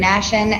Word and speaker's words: Nashan: My name is Keegan Nashan, N-A-S-H-A-N.